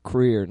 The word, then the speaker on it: Krier: No, it's James Samuel Creer Norton. – Creer? – Yeah. – Can you spell that? – K-R-I-E-R.